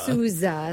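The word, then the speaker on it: Souza: He didn't play in a band. Steven Sousa. – Sousa. Sorry, right, got to say it right.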